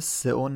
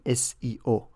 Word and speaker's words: SEO: در از چیزی به اسم سئو نداریم، اینطوری باید تلفظش کنیم. S اول کلمه سرچ هست، E اول کلمه انجین، O اول کلمه اپتیمایزیشن، یعنی SEO Search Engine Optimization، یعنی بهینه‌سازی موتور جستجو.